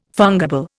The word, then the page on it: fungible: Indian